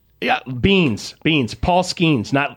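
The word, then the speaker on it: Skenes: Name rhymes with Skeens. – Yeah. Isn't it just Skeens? – I think so.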